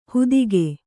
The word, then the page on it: ಹುದಿಗೆ: hudige